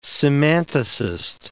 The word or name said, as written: semanticist